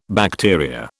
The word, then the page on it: bacteria: British English